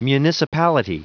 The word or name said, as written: municipality